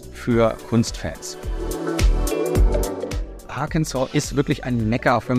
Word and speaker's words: Arkansas: Ich habe es auch gerade mal in einer TV-Sendung wieder falsch gesehen, weil es gibt ja einen anderen Bundesstaat Kansas, aber Arkansas wird tatsächlich französisch ausgesprochen. Das ist ein altes Wort der Quapaw-Indianer.